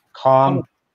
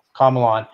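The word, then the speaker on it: Komelon: The Comalon one? Now, yeah. – Now that's actually truly how you say it. You say it like Comalon. Comalon.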